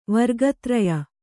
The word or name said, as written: ವರ್ಗತ್ರಯ